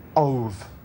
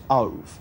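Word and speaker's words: ov (אוב): ov. It's spelled O-B-E when you transliterate it into English, but it's pronounced ov.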